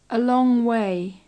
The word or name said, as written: a long way